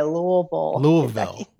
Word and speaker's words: Louisville: That's how you say it, by the way. Louisville, home of Muhammad Ali.